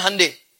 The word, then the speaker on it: Hyundai: Hyundai dibacanya Hyundai.